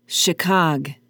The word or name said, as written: chicog